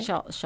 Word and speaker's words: Muscle Shoals: But that's the thing is that they went to Muscle Shores, right? Is that how you say it? – Shoals.